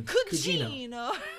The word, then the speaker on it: cugino: So you're not pronouncing it correctly. – What do you mean? – Cugino.